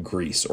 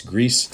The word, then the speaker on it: Gris: Many people love Grease or Gris as some people pronounce it. I think it's Greece technically from the developers, but um, so that is now a mobile game that you can play on iOS.